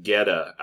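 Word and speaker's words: goetta: What Tyler is describing, I believe, is called Geta outside of Cincinnati.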